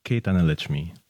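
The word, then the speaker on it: K. Thanaletchimi: How do I say that? – K. Tanalechmi. – Tanalechmi. K. Yeah. Tanalechmi.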